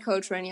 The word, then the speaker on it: Coltrane: Okay, ich habe noch, vielleicht, ich glaube, das wissen die meisten, also es gab zwei Hagrids, einmal Robbie Coltrane oder wie auch immer, ich bin ganz schlecht im Namen aussprechen, ich kann das echt nicht. – Robbie Coltrane, ja, stimmt.